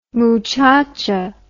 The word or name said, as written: muchacha